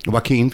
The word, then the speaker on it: Joaquin: It was like, I started to catch on towards the end of the Jacqueline Phoenix, or Jacqueline, or however you say his name. – Joaquin Phoenix? – Joaquin, that's easy.